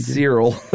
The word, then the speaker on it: Searle: S-E-A-R-L-E, Cyril? – Cyril. I want to hear you say it again. – Cyril.